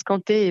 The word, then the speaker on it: Arbacanté: Et puis, on a laissé quand même un accent aigu sur le E de Kanté, parce que on avait envie que les gens le prononcent Kanté et pas Kant.